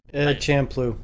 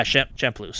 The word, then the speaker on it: Champloo: The more I play of the game, the more I enjoy the parts that feel like Samurai Champloo, a or Champloo. I think I'm pronouncing that right. And the less parts that feel like Rurouni Kenshin. – Champloo. – Yeah, Champloo. Sorry.